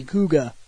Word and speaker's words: Kuuga: Alright, here we are, back with the Sentai stuff, and we are on, I think I said his name right, Kuga. It's like K-U-U-G-A, Kuga, I think.